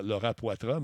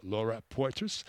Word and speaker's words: Laura Poitras: Euh, d'une madame qui s'appelle euh, Laura Poitras, ou en français Laura Poitras, mais elle prononce vraiment Laura Poitras.